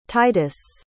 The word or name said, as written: Titus